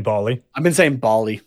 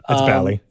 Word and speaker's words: Bally: I've been saying Bally. Yeah. So my apologies. – Like Bollywood. – Yeah. – Yeah. No, it's Bally.